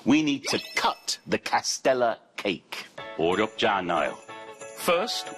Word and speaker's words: castella: 그리고 발음 기억하세요. Castella. 아니야, castella. 또 아니고 castella. 네, 라라라라라. 노래처럼 해주세요.